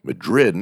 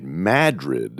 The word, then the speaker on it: Madrid: They've even altered the pronunciation of the name. It's no longer Madrid. Now they're calling it Madrid.